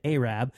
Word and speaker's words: Arab: Allegedly over the weekend, he climbed a 40-foot pine tree in Arab, Alabama, or as they probably pronounce it, Arab, without wearing any clothes, which sounds painful.